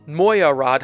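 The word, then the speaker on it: Mogyoród: Round 14 of the season is the Hungarian Grand Prix, held at the Hungaroring in Mojarod, Hungary. I'll tell you, I had to look up how to pronounce that one. It's spelled M-O-G-Y-O-R-O-D. Wrap your mind around that one.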